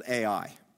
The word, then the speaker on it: Ai: That they saw this little town in the distance called Ai. It's literally just AI. That's how it's spelled, AI.